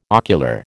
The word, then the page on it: ocular: American English